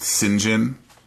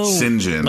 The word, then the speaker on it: St. John: Well, we ever talked about the name St. John, S T J O H N, pronounced Sinjin? No.